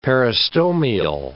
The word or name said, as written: peristomial